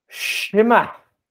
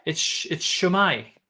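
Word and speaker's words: Shwmae: S-I-S-I-W. – Wait, I have to grab my pen. S-I-W. – M-A-E. – Shima. – It's not bad. It's a good effort. – Really? – It's Shumai.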